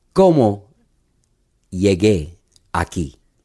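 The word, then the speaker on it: Cómo llegué aquí: How do I say it? – Como llegué aquí.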